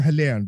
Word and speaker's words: Helen: Is it Helen or Helene? Do the Swedes call you Helene here? – Yeah, they do. They always get my name wrong, but it's Helen.